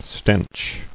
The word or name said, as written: Stench